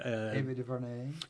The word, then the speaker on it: Ava DuVernay: Ava DuVernay.